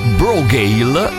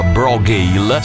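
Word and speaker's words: Brògeal: Si chiamano Brogail, si scrive Bro con la O accentata Geal, ma si pronuncia Brogail.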